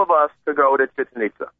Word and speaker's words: Chichen Itza: Chichen Itza, like chicken pizza.